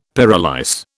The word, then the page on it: pyrolyse: British English